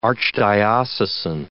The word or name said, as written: archdiocesan